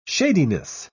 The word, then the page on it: shadiness: British English